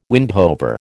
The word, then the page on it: windhover: American English